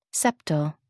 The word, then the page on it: septal: American English